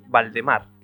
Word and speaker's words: Valdemar: y más tarde como el Conde Valdemar, que yo siempre lo pronunciaba mal, porque me venía lo de Voldemort, como el de Harry Potter, pero no es Valdemar, realmente.